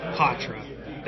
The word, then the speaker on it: Poitras: Matt Poitras, that is correct? – Yeah, that's how it's pronounced, yeah.